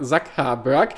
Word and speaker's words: Zuckerberg: Es ist übrigens genauso wie bei King Charles III, genau das die richtige Ausspracheweise ist, ist es bei Mark äh, Zuckerberg, Mark Zuckerberg. Äh, was äh, hat er heute getwittert, äh, geschrieben?